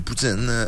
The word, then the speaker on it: poutine: C'est comment ça se prononce, poutine? Pour poutine. – Poutine. – Ah oui, poutine.